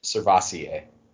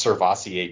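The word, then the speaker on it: Sirvocea: Servosea Dennis? – Servassier. – Like Crevassier? – Kind of. Yeah, it's pronounced Servassier Dennis.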